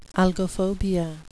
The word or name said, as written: algophobia